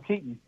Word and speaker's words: Keaton: I want to tell everyone, the way your last name is spelled makes me want to say your name like the actor Michael Keaton.